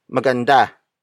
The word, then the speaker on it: maganda: which is maganda. Maganda. Maganda. Maganda is spelled as M-A-G-A-N-D-A.